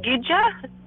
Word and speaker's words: kija: We pronounce Gija. The K actually makes a G sound.